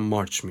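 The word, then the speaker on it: March: که تلفظ انگلیسیش هم مارچ میشه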